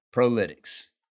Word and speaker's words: Prolytx: You know, it's spelled Prolytx. Asked Mike, Blake, you weren't in here. Asked Mike, I said, hey, is it Prolytics? Am I pronouncing that properly? And he said, sometimes you get like, you know, Prolite, Prolytics probably.